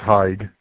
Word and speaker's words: Haig: It's Haig. – Haig. – It's pronounced as if it was spelled H-A-Y-K.